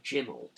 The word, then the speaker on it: Jim'll: Savile is credited with giving the name to this show, stating in numerous interviews that when it was presented to him, he pronounced it Jim'll Fix It. Producers initially thought that he meant the name of the show will be Jim Will Fix It. According to Savile, however, it was Jim'll, J-I-M-apostrophe-L-L.